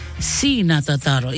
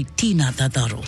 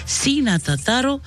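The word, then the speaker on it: Ti na tātaro: And T-I is C, is pronounced C, okay, not T. So the tātaro, the prayer in Kiribati goes something like this. Let us pray, our Heavenly Father, we ask for your blessings this week to each and every one of us in Jesus' name. Amen. So you are going to say this. C na tātaro, it's spelled T-I. You can't say Tina Tataro.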